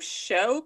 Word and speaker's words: Shou: How do you say his name? Shou Kosugi? – Yeah. S-H-O. Shou. – Okay. – Kosugi. – Maybe that's our S-G-G-I.